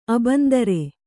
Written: ಅಬಂಧುರ

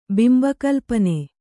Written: ಬಿಂಬಕಲ್ಪನೆ

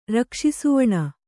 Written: ರಕ್ಷಿಸುವಣ

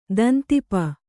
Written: ದಂತಿಪ

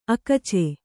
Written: ಅಕಚ

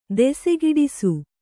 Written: ದೆಸೆಗಿಡಿಸು

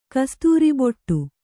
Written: ಕಸ್ತೂರಿಬೊಟ್ಟು

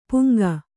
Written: ಪುಂಖ

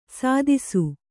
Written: ಸದಸ್ಸು

